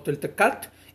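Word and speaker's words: toltecatl: se puede pronunciar como toltecat,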